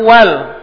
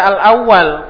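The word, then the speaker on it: uwal (الأُوَل): Wal qaumul uwal, dan kaum-kaum yang awal. Al uwal adalah jamak dari al ula. Al ula adalah jamaknya uwal. Jadi jangan dibaca al awal, keliru.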